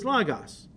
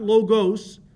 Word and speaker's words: logos: You see, in Greek, the word John uses for the word is logos. Logos, not logos. That's a mispronunciation. It's logos, okay?